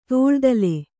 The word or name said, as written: ತೋಳ್ದಲೆ